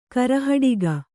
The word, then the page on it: ಕರೆಹಟ್ಟಿಗ: karehaṭṭiga